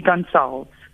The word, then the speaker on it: Gonçalves: Please confirm, have I pronounced your surname correctly? If not, kindly correct it. Live on air. – Hi, Songezo. It's actually Gonçalves.